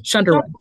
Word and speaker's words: Shunderwood: Shunderwood. Shunder like thunder.